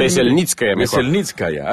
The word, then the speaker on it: Veselnitskaya: Es Natalia Veselnitskaya. ¿Me lo pronuncié bien? – Veselnitskaya, ahí está.